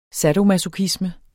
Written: sadomasochisme

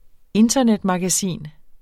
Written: internetmagasin

